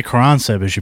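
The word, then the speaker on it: Caransebeș: If you want to pronounce it correctly, it's Caransebish, I guess. – Caransebish. – But Caransebish is right.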